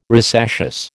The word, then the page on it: rosaceous: American English